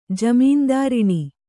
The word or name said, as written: ಜಮೀನ್ದಾರಿಣಿ